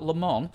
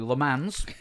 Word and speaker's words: Le Mans: And I have won my class at Le Mans. Sorry, it's a total shunt. I've got to say it properly. Le Mans.